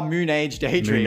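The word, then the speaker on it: Moonage Daydream: Is that how you pronounce it? – Oh, Moon Age Daydream. – Moon Age, my bad. – That's David, you know, wake up in a Moon Age Daydream.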